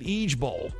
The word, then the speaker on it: IJBOL: You're supposed to pronounce it Ege Bowl.